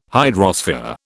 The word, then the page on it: hydrosphere: British English